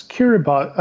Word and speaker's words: Kiribati: It, it's Kiribati. I've heard different pronunciations, and I would say Kiribati because that's the first when I first heard about. That's how it was. I heard it pronounced, but uh, it's it's something like that, Kiribati.